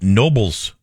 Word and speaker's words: Knoebels: It's called Nobles Amusement Park. That's spelled K-N-O-E-B-E-L-S.